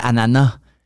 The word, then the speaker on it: ananas: Non, mais c'est comme quand Farid, dit des ananas, puis il savait pas qu'au, Québec, on disait ananas, qu'on, prononçait pas le S.